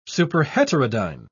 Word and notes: superheterodyne: British English

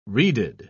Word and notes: reeded: British English

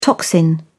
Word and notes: toxin: British English

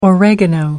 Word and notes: oregano: American English

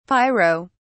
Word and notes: pyro-: British English

